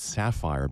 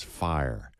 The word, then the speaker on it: Sfair: Just to make it clear, her last name is spelled S-F-A-I-R, and it looks like it's sapphire, but it's fire.